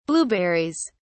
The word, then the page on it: blueberries: British English